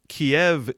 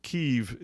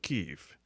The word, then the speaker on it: Kyiv: is that Kiev is the Russian pronunciation, Kyiv is the Ukrainian pronunciation. So that being said, I'll call it Kyiv.